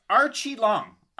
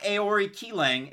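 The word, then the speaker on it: Aoriqileng: So, it's Archie Long. Archie Long, not Aori Keelang. It's Archie Long, if you're going with the pronunciation.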